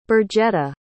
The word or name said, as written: birgetta